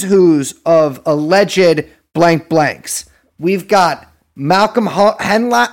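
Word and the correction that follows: Hoenlein: I don't know how to pronounce this fucking guy's name. – Honlein.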